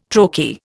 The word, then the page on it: trochee: Indian